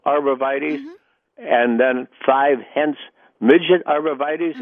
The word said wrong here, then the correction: Hetz Midget: One is techni and the other is hetz midget.